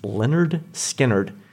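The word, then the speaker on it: Lynyrd Skynyrd: We're produced fabulously by Aaron Adams, who'll be covering the entirety of the breakthrough 1973 album pronounced Leonard Skinnerd,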